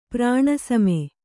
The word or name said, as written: ಪ್ರಾಣಸಮ